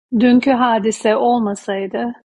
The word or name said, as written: hadise